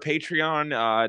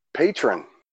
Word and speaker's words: Patreon: Patreon, I believe, is how it's pronounced. – I think so. – What were you pronouncing it as? – Patron. – No, that's technically could also be that, but I think it's like Patreon's the name of the website.